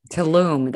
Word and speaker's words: Tulum: And Tulum was, I think, my fifth retreat that I've done, actually. – Tulum. That's how you said it. I probably mispronounced it. That's okay. Tulum.